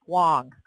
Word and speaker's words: Wang: Remember when Chenmin Wang played? Or, I can never say his name correctly. – Wong? – Played for the Nats. Wong. I always say it wrong.